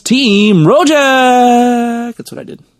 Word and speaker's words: Rojak: Pronounce it like a local. Rojak. – Rojak. – Rojak. – Rojak. – Yes, not Rojak.